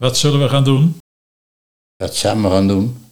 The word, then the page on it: Wa zamme gaan doen: Nieuw lekkerlands; dialect